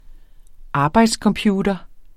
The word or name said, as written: arbejdscomputer